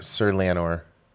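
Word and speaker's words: Laenor: Lanel or Corlys's son. – Yeah, Sir Lanor Valeria.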